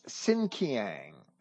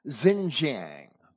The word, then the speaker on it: Xinjiang: This Xinjiang, as Comparet pronounces it, or Xinjiang, Xinjiang, is how it's spelled in modern dictionaries and encyclopedias.